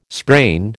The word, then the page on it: sprain: American English